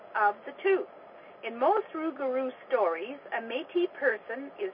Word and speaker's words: rougarou: and found there was one about a rougarou. This is a Michif pronunciation of the French phrase loup-garou, werewolf or goblin.